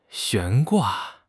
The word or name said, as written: 悬挂